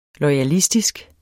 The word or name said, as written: loyalistisk